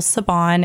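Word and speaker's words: Siobhan: So you can follow me at The Real Savon, and I'll spell that because I know my name's a little difficult.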